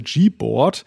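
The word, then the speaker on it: Gboard: ja, höchstens, wenn man sich ein bisschen dumm anstellt, kann man es falsch aussprechen, aber äh, allgemein äh, ist, glaube ich, üblich die Aussprache G-Board.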